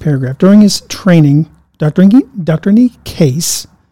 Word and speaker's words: Necaise: Kneecaps. – No. – Necaise. – Necaise. That's how you remember how to say it.